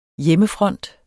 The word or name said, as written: hjemmefront